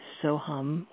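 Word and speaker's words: svāhā: But it sounds much, it's doesn't have the ma consonant at the end, so it's pronounced almost like a sigh. Soha, soha, soha. It's more, it's spelled in the English spelling, the Roman script. It's S-A-U-H. And that H is sounded as a kind of a slight ha.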